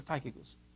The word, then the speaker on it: Tychicus: How do you say it? Tychicus or Tychicus? Tychicus. Thank you.